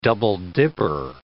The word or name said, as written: double-dipper